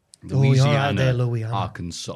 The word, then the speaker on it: Arkansas: Which is spelled Arkansas, yeah. But it's a silent S.